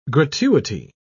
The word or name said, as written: gratuity